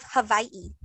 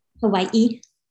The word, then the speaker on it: Hawaii: Today, we are going to be discussing the Night Marchers of Hawaii. That's how you say it, by the way. – Hawaii. – It's Hawaii. – Hawaii. – Hawaii? It's not Hawaii. – It's so Hawaii. It's like a V sound? – Yeah.